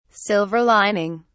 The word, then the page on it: silver lining: British English